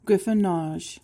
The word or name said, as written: griffonage